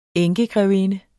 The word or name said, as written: enkegrevinde